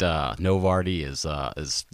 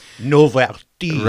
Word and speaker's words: Novartis: I didn't realize that it was the French pronunciation, so I appreciate Novartis. There you go. – Novartis.